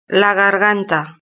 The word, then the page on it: la garganta: Spain Spanish